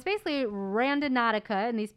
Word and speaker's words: Randonautica: The name of the app is I had said it wrong. It's Randonautica. – Yeah. – I was calling it random Nautica because that's what I thought it was like.